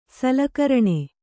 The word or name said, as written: ಸಲಕರಣೆ